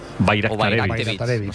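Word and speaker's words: Bajraktarević: Bayraktarevich. – O sea, la J la conviertes en ahí, Bayraktarevich.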